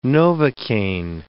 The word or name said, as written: novocaine